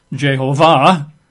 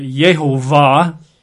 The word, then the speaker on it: Jehovah: We spent all last week talking about, for example, Jehovah, which I think is a fairly good way of pronouncing it, although technically the emphasis would be on the final syllable, Jehovah. Some people that like that particular word but aren't comfortable with the J sound, they pronounce it Yehovah. But of course, in English, for centuries, we've called it Jehovah, emphasizing, the second syllable.